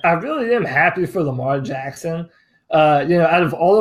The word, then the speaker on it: Lamar Jackson: And then how do you figure this out with Lamar Jackson there? I said it right. I always forget, right? Is it Lamar Jackson, right? – Yeah, Lamar Jackson.